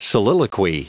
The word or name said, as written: soliloquy